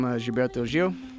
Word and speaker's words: Gilberto Gil: And then we went into some Gilberto Gil.